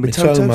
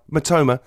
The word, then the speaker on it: Mitoma: I know, can never say his name. – Mitoma. – Excuse me, Mitoma. He's obviously hungry.